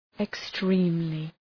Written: extremely

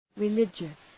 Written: religious

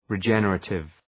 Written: regenerative

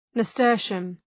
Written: nasturtium

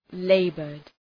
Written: labored

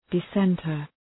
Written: dissenter

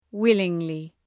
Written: willingly

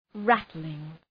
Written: rattling

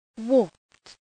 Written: warped

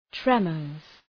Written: tremors

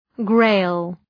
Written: Grail

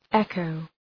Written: echo

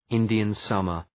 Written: Indian summer

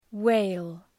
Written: whale

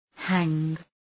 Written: hang

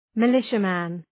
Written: militiaman

